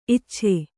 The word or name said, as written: ಇಚ್ಛೆ